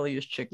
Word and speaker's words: Juszczyk: And fullback again, very easy, like Kyle Juszczyk.